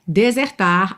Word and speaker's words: desert: D-E-S-E-R-T. D-E-S-E-R-T. Mas a sílaba tônica é a primeira e esse é aberto. Então a gente fala desert, desert.